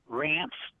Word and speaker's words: Rance: Rance. – And how do you spell that? – Can you hear me? R-A-N-C-E.